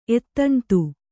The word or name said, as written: ಎತ್ತಣ್ತು